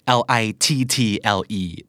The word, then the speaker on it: little: little L I T T L E แต่ออกเสียงเหมือน L I D D L E เป๊ะเลย